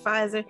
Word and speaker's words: Pfizer: Was it Pfizer? – Yes.